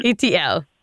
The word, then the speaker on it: Itiel: My name is uh, Dr. Itiel, and you pronounce Itiel like the letters ETL, you know, the movie ET, so it's an L. – Itiel.